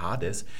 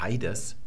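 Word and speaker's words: Hades (ᾍδης): Hades. Wir sagen nicht Heides, ja, sehen wir, dass dieses I hier, das sieht aus wie ein normales Iota, es wird aber nicht ausgesprochen.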